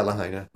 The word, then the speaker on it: Lahaina: You pronounce it Lahaina. – Lahaina. – I've always pronounced it Lahaina, right? – Yeah, Lahaina. That's right.